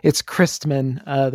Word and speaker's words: Kreisman: It's Kreisman, the first syllable.